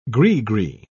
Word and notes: gri-gri: British English

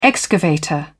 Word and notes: excavator: British English

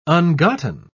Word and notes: ungotten: British English

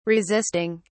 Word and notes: resisting: British English

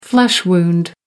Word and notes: flesh wound: American English

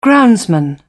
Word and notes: groundsman: British English

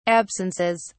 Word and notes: absences: British English

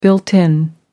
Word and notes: built in: American English

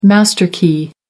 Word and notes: master key: American English

